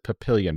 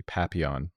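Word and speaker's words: Papillion: The city, I think it's pronounced Papillon, but I think it was originally supposed to be Papillon, which is French for butterfly.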